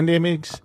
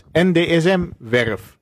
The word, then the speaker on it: NDSM-werf: Speciaal voor onze broodpersoon, de, NDMX, de NDSMX podcast. Zeg ik het goed? Ja, redelijk. Maakt Radio Noord Amsterdam dit jaar een aantal items en shows rondom de NDMX, NDSM-werf. Heb ik je zenuwachtig gemaakt? – Ik kan ik het helemaal niet meer.